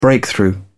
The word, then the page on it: breakthrough: British English